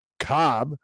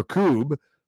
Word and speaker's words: Koob: Director of the National Institute on Alcohol Abuse and Alcoholism, NIAAA, George Cobb, or Koob, you could pronounce it either way, told the Daily Mail on Thursday the U.S.